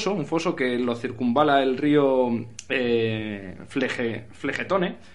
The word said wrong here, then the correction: Flegetonte: Flegetonte, siempre lo digo mal que es el río del fuego,